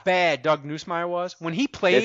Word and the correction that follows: Nussmeier: It's Nussmeier. – Nussmeier, of course, I butchered the name. Nussmeier.